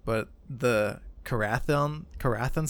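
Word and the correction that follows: Karaethon: cycle, the prophecies Koreathon. Of the Koreathon. Gabe coming in clutch with the pronunciation.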